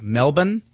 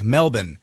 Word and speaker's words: Melbourne: Melbourne, Australia, I guess it's Melbourne. – Melbourne. – Mm-hmm. That's the way you say it, more like Melbourne.